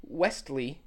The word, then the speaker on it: Westley: There is a T in there. – No. – Yes. I looked it up. There's a T. – But everyone calls him Wesley. – It's fine. The T is silent.